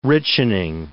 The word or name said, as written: richening